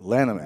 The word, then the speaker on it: Lanham: and it's pronounced the Lanham Act. – It's the Lanham Act. – Lanham Act.